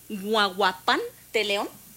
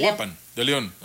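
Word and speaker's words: Huajuapan de León: Eso como parte, bueno, de la hermandad que tenemos, tanto Morelia, a ver si lo puedo pronunciar bien, es Huahuapan de León. – Huahuapan. – Huahuapan de León. Huahuapan de León.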